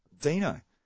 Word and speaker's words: Dino: But Dino, that's how you say it. – All right, well, that's good. That's Dino. Good. If you've got any views on whether it's... – That's how the Italians say it anyway, so, Dino. D-I-N-O.